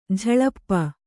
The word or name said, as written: ಝಳಪ